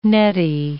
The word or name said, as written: netty